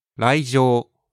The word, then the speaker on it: 来場: まずは、来場。 – 来場 means coming to a place or event. – 来るという漢字と場所の場です。場。の読み方が上に変わります。